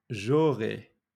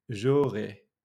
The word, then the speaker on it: J'aurai: J'aurai. J'aurai. I will have. J'aurai and that's spelled J apostrophe A U R A I.